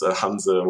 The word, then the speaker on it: Hanse: It's a German word. It's Hanse, which is kind of the architecture, like the way the architecture is called, where I grew up in Germany.